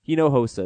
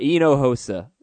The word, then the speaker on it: Hinojosa: Hinojosa. There you go. – I'm not sure I got the accents right, but yeah, the H is silent and the J makes the H sound. – Oh, right. Hinojosa.